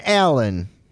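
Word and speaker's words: Allen: Not Ellen. Alan. Well, his name is Alan Toussaint.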